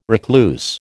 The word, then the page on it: recluse: American English